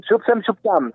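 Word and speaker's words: Šup sem, šup tam: Shubsen Shubtam, ¿lo dije bien? – Vale, sub Sem sub Tam.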